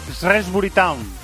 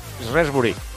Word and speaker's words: Shrewsbury: Al Liverpool le toca a las 6 en el campo del, pronúncialo tú, Shrewsbury Town. – Shrewsbury. – Shrewsbury.